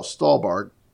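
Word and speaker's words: Stuhlbarg: Michael Stut, I can never say his name. Oh, Stahlbarg. Michael Stahlbarg is another key role.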